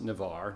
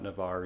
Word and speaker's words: Navarre: If you've been looking into Navarre, it's actually pronounced Navarre, not Navarre. I've heard a lot of people make that mistake.